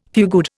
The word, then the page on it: fugued: Indian